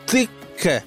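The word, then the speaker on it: تکه: البته من از ایلیا جزائری رفیق و همکارم که عربی بلده پرسیدم تلفظ درست این غذا چیه، گفت تیکه.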